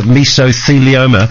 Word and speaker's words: mesothelioma: We've been speaking about the dangers of asbestos and how just a tiny speck of it can cause the cancer mesothelioma.